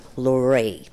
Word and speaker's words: Lorrae: So after we'd done Sydney and Melbourne and then done the television special, he said, "Now listen, Lorraine," that's how you pronounce my name, Lorraine.